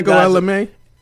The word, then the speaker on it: Ego Ella May: Ego Ella May? – That's how you say it? – Yeah, Ego. – Did I say ego? – Yeah, you did. It's all good.